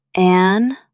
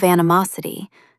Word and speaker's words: animosity: When rivals let go of animosity, true healing can begin. Stress the third syllable, animosity. Try saying it, animosity.